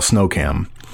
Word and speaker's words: SNOHKAM: Look, something no one here knows about me, or what we call snowcam, it, and that's that's essentially the pronunciation of the acronym S N O H K A M.